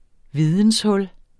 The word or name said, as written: videnshul